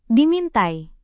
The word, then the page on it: dimintai: Indonesian